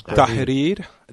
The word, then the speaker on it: Tahrir: Tahrir. Thank you. Tahrir. Tahrir. – Tahrir. – Do, can I yes. get a, a, a lesson in pronunciation? You just pronounced it right.